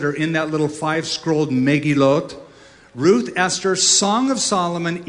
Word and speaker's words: Megilloth: This book, the Book of Lamentations, is part of a section of the Bible called the Megilloth. I'm pronouncing it, Americanizing it on purpose. Megilloth, or Megillot is the Hebrew pronunciation.